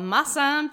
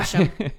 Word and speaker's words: Mosheim: Where I grew up, I grew up in a little town called Mossam, Tennessee. Mossam, Tennessee. Greene County. So. And it's spelled M-O-S-H-E-I-M. – As if it were, yeah. – Mosham. – If it were European, it would be Mossheim.